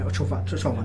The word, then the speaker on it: Ciofani: E come essere sembra essere vicino anche Ciofani, Ciofani, ho detto bene, sì.